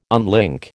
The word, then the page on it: unlink: American English